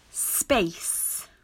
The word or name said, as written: Space